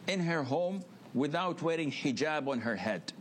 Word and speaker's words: hijab: And now I want to go to this next clip, and this is going to be the hijab. That's how you pronounce it, the hijab.